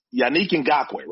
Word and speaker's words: Yannick Ngakoue: It's Yannick Ngakoue, right? – Yannick, yes.